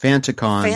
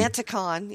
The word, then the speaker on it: Fanaticon: So I recently learned it's pronounced Fanticon. – Fanticon, yes. – It's spelled Fanaticon, but it's pronounced Fanticon.